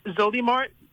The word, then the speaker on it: Zolymar: It's Zolymar, Z O L Y M A R, and her last name is Luna, L U N A.